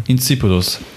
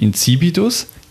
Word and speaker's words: insipidus: Incipidus. Ja, die Betonung ist wichtig. Incipidus, Incipidus. Incipidus? Incipidus, ja. Genau,